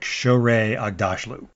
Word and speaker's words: Shohreh Aghdashloo: I believe her name is pronounced something like Shohreh Aghdashloo.